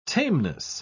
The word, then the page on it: tameness: British English